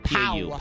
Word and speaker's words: Pau: For a while, I thought it was Paul, but I was like, oh, there's no L at the end. It's just Pau. – Pau Gasol. – Pau Gasol.